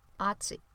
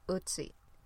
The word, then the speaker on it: Ötzi: I always pronounced it Ötzi, but I found out that it's actually pronounced more like Ötzi.